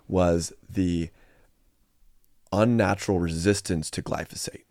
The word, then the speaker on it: glyphosate: And now glyphosate can be sprayed freely on these crops and they won't die.